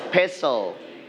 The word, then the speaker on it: pestle: Pestle. – All right. So T, it's silent. T, it's silent. We don't pronounce T. Okay. This one, you can say pestle.